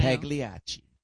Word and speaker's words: Pagliacci: And once again, you know, because I know him, you know, was the how do you pronounce Pac? The clown. – Pagliacci. – You know, yeah. Explain that to. I can't even say the word. – The opera Pagliacci.